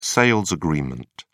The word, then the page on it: sales agreement: British English